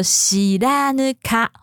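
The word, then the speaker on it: 白糠: 那个康呢是糟糠之妻的康，好，念作西拉呢卡，好，白康这个地方。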